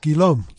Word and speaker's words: Guillaume: It's quite a neat spelling with a different pronunciation. French. – French. Yeah. – How do you spell it? – It's G-U-I-L-L-A-U-M-E. – Pronounced Guillaume.